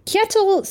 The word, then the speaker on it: Kjetil: Kjetil? So Kjetil? It's K-J-E-T-I-L. – Kjetil sounds right.